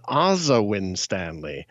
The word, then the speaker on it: Asa Winstanley: Yeah, I just learned that he pronounces his own name Ozawin Stanley.